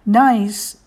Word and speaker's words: gneiss: Nice Packs, G-N-E-I-S-S, Packs, has moved beyond clever wordplay. Nice is pronounced nice, is banded metamorphic rock made from other rocks, to custom river gear, high-quality backpacks, and stylish accessories for mountain living.